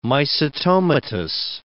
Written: mycetomatous

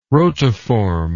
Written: rotiform

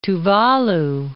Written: Tuvalu

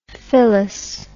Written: phyllous

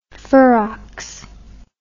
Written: ferox

